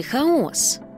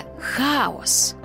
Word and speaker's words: хаос: До речі, то як правильно наголошувати? Хаос чи хаос? Наголос у цьому слові залежить від його значення. Якщо йдеться про давньогрецьку міфологію, а саме про безмежний світовий простір, як суміш усіх стихій, як безодню, з якої витворилося усе, то це хаос. А хаос – це безлад, плутанина, відсутність порядку, гармидер.